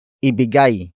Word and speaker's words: ibigay: Ibigay, mo, si, Terry, nang, maiinom.